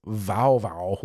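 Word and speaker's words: Wau wau: Yeah, but in German it's not bow wow. It's vow vow. Like kind of.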